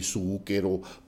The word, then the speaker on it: Zucchero: Se escribe Z-U-C-C-H-E-R-O, e sí, Zucchero, Zucchero en realidad.